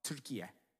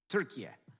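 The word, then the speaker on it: Türkiye: I want to tell you that Turkey is a, now they want us to pronounce it now, Turkiye. Turkiye.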